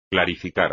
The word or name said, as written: clarificar